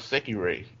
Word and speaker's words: Sekirei: Yeah, I've been watching an anime called Sekirei.